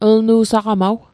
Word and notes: lnu saqamaw: Mi'gmaq

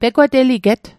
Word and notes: pegwateliget: Mi'gmaq

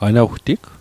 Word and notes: anawtig: anawtik

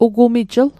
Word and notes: ugumijl: Mi'gmaq

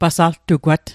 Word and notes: pasaltugwat: Mi'gmaq

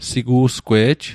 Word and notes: sigu'sgwe'j: Mi'gmaq

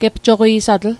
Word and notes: gepjoqi'satl: Mi'gmaq

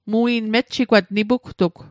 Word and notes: mejjigwat: Mi'gmaq

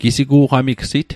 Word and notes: gisigu'qamigsit: Mi'gmaq

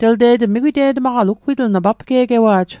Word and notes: papge'gewa'j: Mi'gmaq

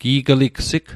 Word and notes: gi'gligsig: Mi'gmaq

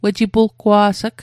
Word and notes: wejipulgwa's'g: Mi'gmaq

